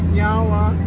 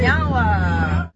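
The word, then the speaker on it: Niá:wen: So in our language, we say Nyawa, which means thank you. – Nyawa. – Nyawa. – I said it right? – Yeah, you did. – Nyawa.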